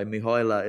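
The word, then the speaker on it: Mihăilă: And I think you're dead right that Man and Mihoyla, I've asked a Romanian person, by the way, Mihoyla is the pronunciation of that.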